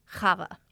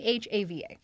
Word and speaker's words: Chava: It's pronounced, I'm going to do my best, best Yiddish, Chava. Hava. Hava. Okay, yeah, no, I did not say that right in my head. It's C H A V A. Yes. Hava.